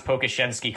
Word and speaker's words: Pokuševski: Yeah, and by the way, you did pronounce Pokuševski correctly, at least to my knowledge.